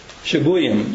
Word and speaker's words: Shabuyim: Shabuyim is how you say this.